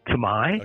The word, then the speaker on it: Thmei: It's spelled T-H-M-E-I, and most people pronounce it Tamai.